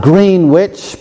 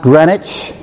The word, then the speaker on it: Greenwich: It looks like Greenwich, but it's pronounced Greenwich, I assure you, just outside London.